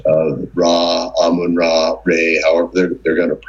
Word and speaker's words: Amun: Amun or Amen, however they wanted to pronounce it, Amun Ra was thus considered the greatest god of Egypt and who was the incarnation? – Pharaoh.